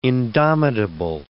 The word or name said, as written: indomitable